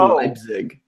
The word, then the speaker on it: Leipzig: Columbus, is this not Lice Pig? Are we doing Red Bulls Lice Pig or New York? – You're mispronouncing Leipzig.